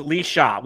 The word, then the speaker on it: Laoise: It says, but Laozi equals Lee Shaw, which... How the hell does L-A-O-I-S-E pronounce Lee Shaw?